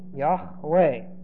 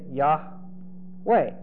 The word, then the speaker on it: Yahweh: The pronunciation of Yahweh was most likely, like I'm saying it, Yahweh. But it's a guess, so I'm putting most likely.